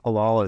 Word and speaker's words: halal: There was a big proclamation that was made where Bitcoin is now, I think it's halal.